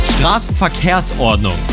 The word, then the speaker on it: Straßenverkehrsordnung: The STVO, as it's called in Germany, this Straßenverkehrsordnung is how you say it in Germany, the STVO.